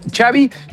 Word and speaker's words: Xavi: Y él dijo, Xavi como chocolate.